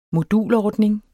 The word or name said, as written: modulordning